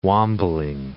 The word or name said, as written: wambling